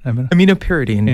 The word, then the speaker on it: aminopyridine: So could you explain what 4-aminopridine, aminopridine, did I say that right? – Aminopyridine.